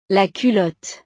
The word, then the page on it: la culotte: frances